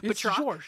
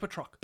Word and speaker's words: Batroc: But Georges, I don't think I'm pronouncing that correct because it's a French name. – Batroc. George Batroc.